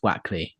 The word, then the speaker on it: Wakely: It's written Wakely, but it's pronounced Wackley.